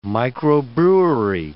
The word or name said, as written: microbrewery